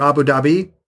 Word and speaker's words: Abu Dhabi: It's only the second ever Abu Dhabi marathon.